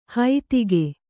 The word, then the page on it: ಹೈತಿಗೆ: kannada